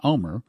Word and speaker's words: Olmert: However, if Ehud Olmert, or O-L-M-E-R-T, correctly pronounced Omer, if he gets involved, that means that it could be any time in there.